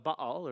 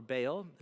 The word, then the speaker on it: Baal: And what you've got here is a picture of a god mentioned in the Bible, the god called Baal, or Baal, is how we butcher it in English, Baal, but they said Baal.